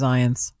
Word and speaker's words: Zients: Zients? I don't even know how to say it. – I'm pretty sure it's Zients. – Zients? – Yeah, like science.